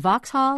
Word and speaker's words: Vauxhall: And we remind ourselves, GM Europe owns Opel and Vauxhall, have I said that correctly? – That's correct.